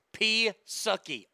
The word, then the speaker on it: Psaki: I hate you keep pronouncing her name or mispronouncing her name. It's Psaki.